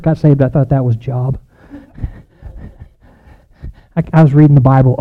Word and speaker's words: Job: And then I realized, long O. Okay, there was a man in the land of Uz whose name was Job, and that man was blameless and upright, one who feared God and turned away from evil.